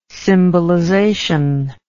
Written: symbolization

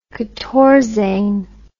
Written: quatorzain

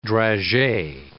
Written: dragee

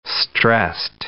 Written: stressed

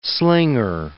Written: slinger

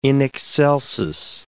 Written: in excelsis

